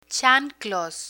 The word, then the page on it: chanclos: spanish